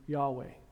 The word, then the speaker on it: Yahweh: I truly believe, after my research, a little bit of research and study that I've done, I believe this is probably the name as God would have spoken it, the best pronunciation we have, God speaking Yahweh.